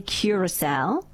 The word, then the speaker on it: Curaçao: The country is definitely Curaçao.